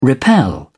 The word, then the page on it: repel: British English